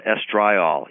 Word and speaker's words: estriol: One of the candidates as an immunosuppressive substance produced during pregnancy is one of the estrogens called estriol, E-S-T-R-I-O-L. Some people pronounce that estriol.